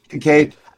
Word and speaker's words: Kincaid: Kincaid.